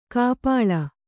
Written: ಕಪೋಳಿ

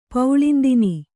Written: ಪುಳಿಂದಿನಿ

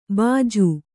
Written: ಭೋಜ

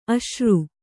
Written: ಆಸರ

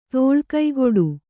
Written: ತೋಳ್ಕೈಗೊಡು

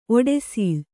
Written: ಒಡಸಲ್

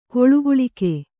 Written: ಗೊಳಗೊಳಿಕೆ

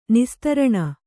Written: ನಿಸ್ತ್ರಾಣಿ

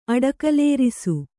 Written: ಅಡಕಲೇರಿಸು

